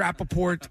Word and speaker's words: Rappaport: By the way, I wanted to ask you before we have to wrap, but I thought I heard you mention or pronounce the T in Rappaport. Is that silent or not? – No, I'm not French. Some people, they go Rappaport, and I'm like, there's a T there for a reason.